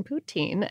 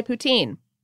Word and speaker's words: poutine: Firstly, on pronunciation, poutine is correct. Poutine is a French-Canadian slang for prostitute and used in the same way an English speaker would use the S or F words. You'll be met with anything from giggles to death stares if you pronounce it that way, especially in Quebec. Poutine is also widely accepted outside of Quebec, they're real purists, though you'll have to be careful with this too, as it can be construed in Spanish as small gay man, and may be, again, met with giggles.